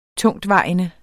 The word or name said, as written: TUNGTVEJENDE